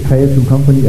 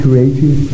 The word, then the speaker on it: Creative Company: De tre firmaer er Maribo Medico, det er Økonomi- og Analysecenter på Høje Taastrup Kommune, og så er det Creative Company, meget bekendt udtales det Creative Company, er det ikke rigtigt? Ikke Creative, nej.